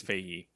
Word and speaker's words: Feige: leaving Marvel Studios and producer Kevin Feige. Is that how you say his name? I think it's Feige. – Yeah. That's how I would say it.